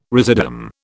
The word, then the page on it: residuum: British English